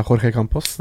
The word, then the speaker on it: Jorge Campos: Og, og etter at uh, må du hjelpe meg litt med den portugisisk-brasilianske uttalen. Eh, Jorge Campos. – Ja, det er Jorge Campos. – Det ja, ja. – Men det er fortsatt uh, portugisisk uttale.